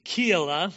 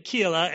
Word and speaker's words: Keilah: Then they told David, saying, "Behold, the Philistines are fighting against Keilah, that's how you pronounce it, Keilah, and they are plundering the threshing floors.